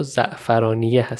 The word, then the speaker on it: زعفرانیه: تلفظ صحیح این روستا زعفرانیه هستش اما تو کتاب زعفرانی نوشته یعنی اون هی آخر رو نداره